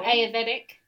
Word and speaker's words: Ayurvedic: Oh, I was scrolling through Facebook the other day, and those how do you say that aerobic Oh, Ayurvedic. Ayurvedic. I've got to get that in my brain.